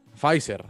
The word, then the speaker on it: Pfizer: Pfizer.